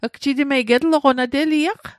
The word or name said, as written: getloqo